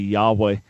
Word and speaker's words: Yahweh: Jehovah is a mistaken pronunciation of the name of the God of Israel, the creator of the universe. The more accurate way to pronounce his name would be Yahweh.